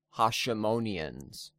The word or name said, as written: Hasimonians